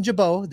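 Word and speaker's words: Gibaut: So after Sessa and Overton, I have this bullpen rounding out with Alexis Diaz, Lucas Sims, Reaver San Martin, Buck Farmer, Ian Jabot, the T is silent, and then, of course, Jeff's favorite pitcher in the world, Hunter Strickland.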